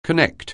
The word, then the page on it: connect: anglais